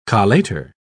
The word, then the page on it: collator: British English